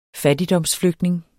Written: fattigdomsflygtning

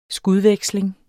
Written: SKUDVEKSLING

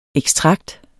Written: ekstrakt

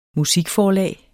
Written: musikforlag

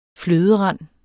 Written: fløderand

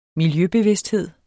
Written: miljøbevidsthed

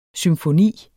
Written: symfoni

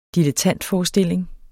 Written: dilettantforestilling

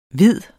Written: hvid

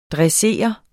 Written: dressere

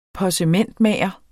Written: possementmager

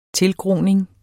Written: tilgroning

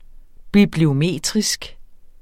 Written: bibliometrisk

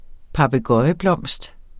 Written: papegøjeblomst

